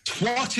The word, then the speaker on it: twat: Twat is not a swear word. Twat is a rejected Teletubby. It is twat. It rhymes twat. With hat. It rhymes with cat.